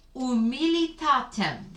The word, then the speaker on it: humilitatem: Quia respexit humilitatem. The H is silent.